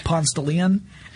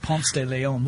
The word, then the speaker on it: Ponce de Leon: There's a section on and I apologize to all the people who are going to hear the pronunciation of this street, but this is how we say it in Atlanta. Ponce de Leon.